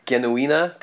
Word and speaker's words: Genuina: Uh, in this film, the lead actress playing the role of Genuine, or Genuina, to use the German pronunciation, is Fern Andra.